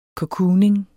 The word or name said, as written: cocooning